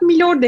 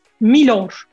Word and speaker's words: Milor: Onun üzerine de konuşmak istiyorum ama yayına girmeden önce sizinle e, sohbete başlamadan önce Vedat Milor demişti. Yanlış oldu. Doğru telaffuz Milor.